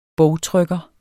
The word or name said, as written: bogtrykker